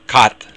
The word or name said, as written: cat